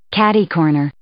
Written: catercorner

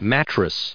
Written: mattress

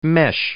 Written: mesh

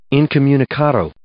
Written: incommunicado

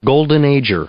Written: golden ager

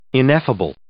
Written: ineffable

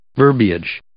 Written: verbiage

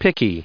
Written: picky

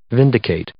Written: vindicate

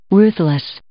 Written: ruthless

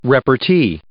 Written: repartee

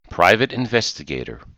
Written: private investigator

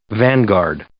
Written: vanguard

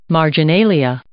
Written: marginalia

